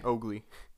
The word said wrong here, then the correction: Mowgli: Mowgli. – Mowgli. Mowgli. Said it wrong. – I said it wrong at first, but then I was like, oh, yeah, Mowgli.